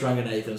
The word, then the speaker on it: Ranganathan: So, in terms of brown comics, it would be Russell Peters, Paul Chowdhury, Ramesh Ranganathan, Sugar Sammy.